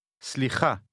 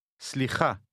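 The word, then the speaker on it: Slicha (סליחה): Slicha. Slicha. – How do you say excuse me in Hebrew? – Slicha.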